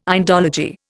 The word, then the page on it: Indology: Indian